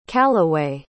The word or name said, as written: calloway